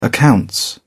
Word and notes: accounts: British English

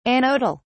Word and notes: anodal: British English

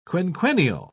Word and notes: quinquennial: British English

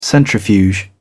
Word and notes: centrifuge: British English

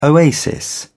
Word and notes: oasis: British English

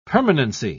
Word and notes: permanency: British English

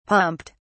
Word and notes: pumped: British English

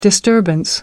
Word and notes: disturbance: American English

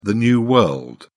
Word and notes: new world: British English

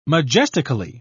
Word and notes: majestically: British English

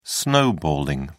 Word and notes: snowballing: British English

